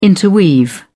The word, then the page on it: interweave: British English